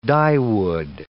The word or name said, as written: dyewood